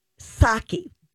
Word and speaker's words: Psaki: What I don't her name is Jan, Jen Psaki but mm-hmm. it, I don't know why there is a P in front of it. It's P S A K I, but you don't pronounce it that way.